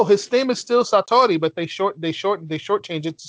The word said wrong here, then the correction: Satoru: Satoru, not Satori.